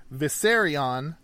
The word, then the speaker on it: Vissarion: Viserion, not spelled like the dragon from Game of Thrones, but probably pronounced the same. V-I-S-S-A-R-I-O-N.